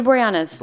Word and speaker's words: Boreanaz: David Boreanaz.